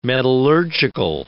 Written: metallurgical

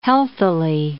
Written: healthily

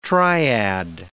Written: triad

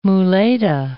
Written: muleta

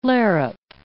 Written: larrup

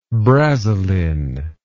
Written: brasilin

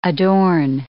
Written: adorn

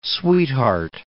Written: sweetheart

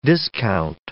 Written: discount

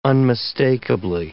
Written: unmistakably

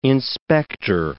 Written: inspector

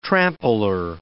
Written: trampler

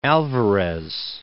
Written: Alvarez

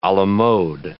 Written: a la mode

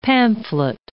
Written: pamphlet

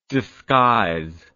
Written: disguiser

